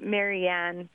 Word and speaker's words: Marianne: Oh, I've always pronounced it Marianne. But I am comfortable with people pronouncing it differently if they want.